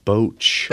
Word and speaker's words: Botch: I think it's pronounced Boach. – Boach.